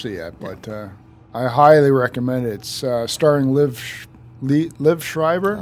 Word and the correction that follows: Liev: Liv Schreiber. – Liev. – Liev. Well, if you know him well, you call him Liv.